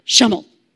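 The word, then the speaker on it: Przemyśl: It's P-R-Z-E-M-Y-L-S. Okay, it's pronounced Shummel.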